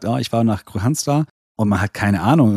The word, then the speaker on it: Kristianstad: Christianstad, das D lassen wir gleich mal wieder wegfallen, hinten dran, also hätten wir noch Christianstad, aber das passt immer noch nicht so richtig. Wie wird dieser Ort ausgesprochen? – Ja, der Ort wird ganz lustig ausgesprochen, das ist nämlich Kristianstad. Kristianstad, ganz langsam, ja? Also da haben wir dieses K-R-I und dann haben wir ein S-T, wo man eigentlich sagen würde, ja, Moment, das S-T wird doch aber ausgesprochen.